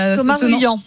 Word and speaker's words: Thomas Ruyant: Bah du coup, je ne sais pas comment on prononce son nom. – Thomas Ruyant. – Thomas Ruyant, bah voilà, bah, lui, il renonce faute de sponsor.